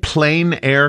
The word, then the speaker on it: plein air: Am I saying that right? Plain Air Competition? – You are saying that right.